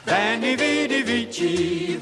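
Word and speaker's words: Veni, vidi, vici: And Ronnie, like the Gaylords, preferred the ecclesiastical pronunciation for Latin. There will be more from Radio Derb next week. – Vanni Vidi Vici, Vanni Vidi Vici.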